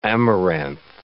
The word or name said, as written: amaranth